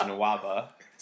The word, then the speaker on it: Nwaba: I said Nwumbo, and it actually is Nwaba. So David Nwumbo.